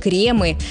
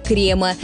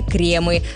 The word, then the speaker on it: кремы: Обращаем ваше внимание также и на ударение. Оно всегда только на «е». И во множественном числе, и в единственном. У нас нет «крема». Мы использовали для торта разные «кремы».